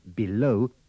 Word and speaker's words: below: In these dialogues, remember to pronounce the first E of below, beneath, beside, and between with the sound of the I in sit.